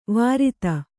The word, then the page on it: ವಿರೋಧ: Kannada